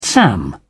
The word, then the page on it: sam: British English